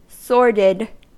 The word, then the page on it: sordid: American English